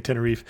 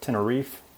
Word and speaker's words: Tenerife: I heard Tenerife with no pronunciation of the E. I've heard Tenerife, like almost like a UH sound, and Tenerife, like F-A-E type sound at the end. I heard all used interchangeably.